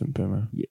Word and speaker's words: Mbeumo: I think it's pronounced Mbeumo. – It depends what area of the world you're in.